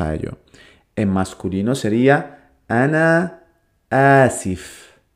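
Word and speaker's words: Ana Asif (أنا آسف): Así que vamos a ver, en masculino era Ana Asif.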